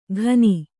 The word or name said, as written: ಖನಿ